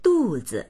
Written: du4zi

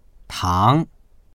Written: tang2

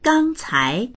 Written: gang1cai2

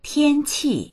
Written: tian1qi4

